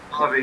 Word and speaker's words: Ravi: Ravi, yeah.